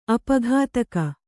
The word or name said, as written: ಅಪಘಾತಕ